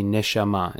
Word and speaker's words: neshama: In Hebrew, that word would be neshama. In English, that's spelled N-E-S-H-A-M-A, neshama, which is a soul, Jewish soul.